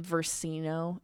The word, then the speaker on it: Versino: It's Lauren Versino. It's spelled exactly how it sounds.